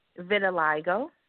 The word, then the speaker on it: vitiligo: Vitiligo.